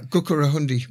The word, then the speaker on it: Gukurahundi: And of course, they saw what happened in Matabeleland just 3 years after that with, I can never pronounce correctly, the Gukurunda. – Yeah, Gukurahundi.